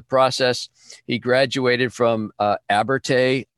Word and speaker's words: Abertay: Abertay is correct.